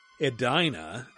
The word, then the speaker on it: Edina: That's right, the first mall was Southdale Center in Edina, pronounced Edina, and it is still in operation today.